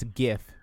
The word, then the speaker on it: GIF: Netflix said, hey, guys, here's your daily reminder that it's GIF with a hard G.